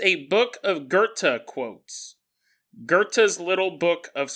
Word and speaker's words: Goethe: Jonathan said his name is actually Goethe. Goethe. I was mispronouncing it, and he wasn't just sending me an email to correct me.